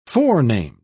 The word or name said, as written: forenamed